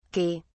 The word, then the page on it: okey: British English